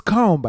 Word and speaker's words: Cologne: It's Cologne, by the way. That's how it's pronounced.